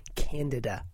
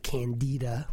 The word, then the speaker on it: Candida: It's Candida, by the way, not Candida, which is sort of a George Bernard Shaw play, nor is it pronounced Canada, which is the country up north.